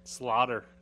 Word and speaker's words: Sloter: I just know that Kyle, how did he pronounce it? Sloter? – Slaughter.